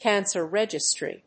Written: cancer registry

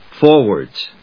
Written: Forwards

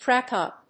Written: track up

